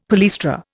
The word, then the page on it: palaestra: Indian